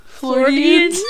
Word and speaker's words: Floridians: All Floridians. Is that how you say it? – Yeah. Floridians. like accordions.